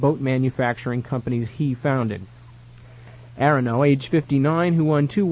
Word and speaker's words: Aronow: Oh, by the way, his name is spelled A-R-O-N-O-W, and I've never heard it pronounced. Is that... – I would say Arono. – Arono, think so, yes. A-R-O-N-O-W.